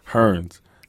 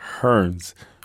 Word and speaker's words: Hernz: I used to say, hey, my name is Hernz, and they said Burns. I said, oh, no, Hernz. Terrence? No, Hernz.